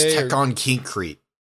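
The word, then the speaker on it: Tekkon Kinkreet: Tekkon Kinkrete. I think I said that right. Remember that? – Oh, yeah, Tekkon Krite or something like that? – Krite, it's Tekkon Kinkrete.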